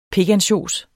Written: pikansjos

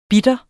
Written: bitter

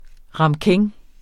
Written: ramekin

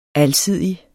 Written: alsidig

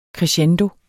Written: crescendo

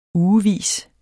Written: ugevis

